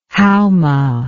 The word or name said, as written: haoma